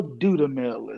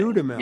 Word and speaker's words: Dudamel: Is that correct pronunciation? – Yeah, Gustavo Dudamel is his name. – Dudamel.